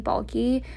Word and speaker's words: Balke: It's at Kaylee Balke. My last name is spelled B-A-L-K-E. A lot of people think it's I-E, but it's not. It's B-A-L-K-E.